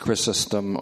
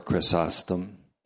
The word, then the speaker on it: Chrysostom: John Chrysostom, can be pronounced Chrysostom or Chrysostom, Chrysostom.